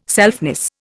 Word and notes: selfness: Indian

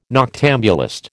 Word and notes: noctambulist: American English

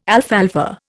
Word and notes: alfalfa: Indian

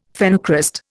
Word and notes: phenocryst: Indian

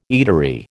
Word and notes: eatery: American English

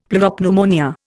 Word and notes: pleuropneumonia: Indian

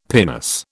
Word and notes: pinnace: British English

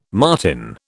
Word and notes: martin: British English

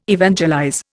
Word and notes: evangelize: Indian

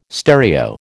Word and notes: stereo: American English

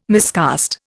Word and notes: miscast: Indian

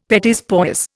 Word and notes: petits pois: Indian

